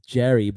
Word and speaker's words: Gerry: It's Jerry, but it's spelled with a G. So it spells like Gary, but I don't know. G-E-R-R-Y.